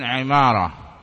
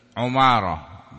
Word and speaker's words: Imarah: Ibn Imarah, anaknya Imarah, bikasril ain. Ainnya dikasrahkan, al-muhmalah. Enggak ada titik. Kalau ada titik, mu'jamah. Kalau ada titik, gimarah. Itu salah bacanya. Muhmalah. Wawal masyhur, dan ini yang masyhur. Waqad tudum, waqad tadumu, dan kadang didomahkan. Berarti Ibn Umarah, bukan Imarah. Tetapi yang kasrah masyhur, bacaan Imarah, Ibn Imarah, Ubay ibn Imarah, bisa juga Ubay bin Umarah, bisa.